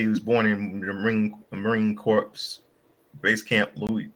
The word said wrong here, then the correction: Lejeune: It was Camp Lejeune is how you pronounce that in North Carolina.